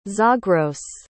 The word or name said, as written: zagros